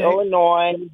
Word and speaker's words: Illinois: But then it is Illinoisans, and people in Illinois, and then the S is not pronounced. It's okay. Illinois.